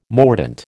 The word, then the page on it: mordant: American English